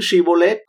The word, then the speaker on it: Shibboleth: Họ đã gặp khó khăn trong việc phát âm chữ sờ là Shibboleth ấy, và thay vào đó họ chỉ nói được chữ si, sờ thôi.